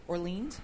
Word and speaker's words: Orleans: The Orlean. Is that how you pronounce Orleans? – It? Orleans.